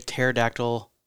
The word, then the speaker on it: pterodactyl: But the dad joke was, how come you can't hear a pterodactyl go to the bathroom? – Anybody out there? – Anybody out there? – Anybody? Because the P is silent.